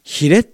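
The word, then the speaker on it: 鰭: 私このタイトル最初に見た時には美しい寿司だと思っていたんですけど、魚編に老人の老、老いるという字の下に日本の日という字を書いてヒレって読むんですね。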